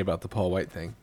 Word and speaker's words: White: Scant few details have come out other than it's Paul White and I'm pronouncing that H every time.